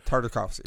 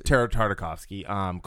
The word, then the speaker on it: Tartakovsky: Tartakovsky. – Tartakovsky.